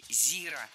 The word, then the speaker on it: зира: В том же словаре, как и во всех остальных, другая приправа имеет ударение на первом слоге – зира.